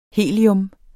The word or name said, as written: helium